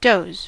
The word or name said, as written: does